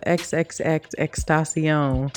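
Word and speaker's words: XXXTentacion: But another positive thing, the murderers of XXX Extasion. That's how you say it, right? Is that correct? – Yes.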